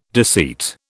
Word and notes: deceit: British English

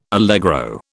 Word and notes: allegro: British English

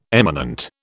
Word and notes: eminent: British English

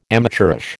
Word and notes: amateurish: American English

